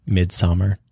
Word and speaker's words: Midsommar: Midsommar.